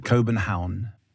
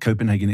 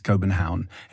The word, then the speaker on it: Copenhagen: Copenhagen. – Wow. – Yeah, it's not Copenhagen, it's Copenhagen, and it's got a K.